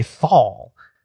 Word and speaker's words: Neanderthal: No, I think it's pronounced in German, tall. But in English, there's an H, so we say thal.